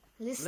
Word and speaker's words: listen: Listen. – Listen. It's written like listen, but T is silent there.